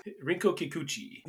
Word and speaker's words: Rinko Kikuchi: Rico Pikachu. That sounds like Pikachu, and that's probably somewhat racist. – Yes, I swear I'm not trying. – Rinko Kikuchi.